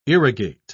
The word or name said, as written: irrigate